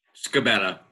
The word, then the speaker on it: Scibetta: Scibetta.